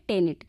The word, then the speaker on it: tenet: और जो दूसरा शब्द है वो है टेनिट, जिसकी स्पेलिंग है TENET।